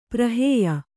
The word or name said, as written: ಪ್ರಹೇಯ